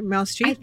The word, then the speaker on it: Meryl Streep: I've shared this before, even at one of your events, but I think I heard that Meryl Streep, I said that right, Meryl Streep, the actor, yeah.